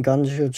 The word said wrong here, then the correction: Guangzhou: Charge. And then second one I realized, you know, maybe I should just use Translate because it can pronounce it better. – Guangzhou. – It does pronounce it better. Thank you, Translate.